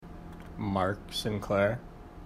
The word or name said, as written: Sinclair